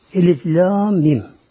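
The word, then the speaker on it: Elif Lâm Mîm: Yani kesik olarak okunuyor. Birleşilmiyor. Mesela elem denmiyor buna. Elif ayrı, lam ayrı, mim ayrı.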